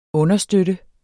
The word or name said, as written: understøtte